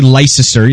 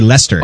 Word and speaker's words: Leicester: You don't say Leicester. You say Lester.